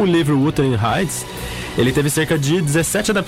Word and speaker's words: Wuthering Heights: Isso aí, ela tá hoje aqui no Sessão das 6, porque o primeiro álbum que ela lançou uma música chamada Wuthering Heights. Nossa, como é que fala isso aqui, Maurício? – Eu acho que é Wuthering Heights. – É, Wuthering Heights,